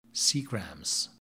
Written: seagrams

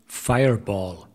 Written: Fireball

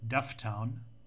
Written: Dufftown

